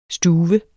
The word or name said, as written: stuve